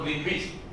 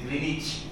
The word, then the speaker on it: Greenwich: It is not Greenwich, it is Greenwich.